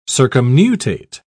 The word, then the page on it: circumnutate: British English